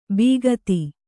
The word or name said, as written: ಬೀಗತಿ